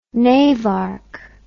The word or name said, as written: navarch